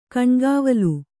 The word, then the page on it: ಕಣ್ಗಾವಲು: Kannada